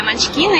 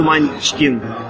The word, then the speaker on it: Munchkin: Скажите, пожалуйста, вот вы как большой специалист, где правильно ставить ударение в слове Munchkin? – Munchkin на И. – На букву И. Спасибо большое. – Munchkin, вот так.